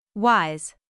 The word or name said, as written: wise